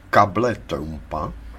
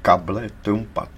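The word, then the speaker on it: cable trampa: Vlastně tuto situaci popsal přesně jako takzvaný plán kable Trumpa. – To se vyslovuje možná kable Trump. – Ne, ne, ne, to se vyslovuje kable Trumpa. To je španělský termín, značící nástražní drát k mině.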